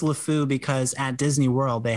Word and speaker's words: LeFou: Did I say his name right? LeFou? I think I messed that up. – LeFou.